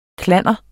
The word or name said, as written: klanner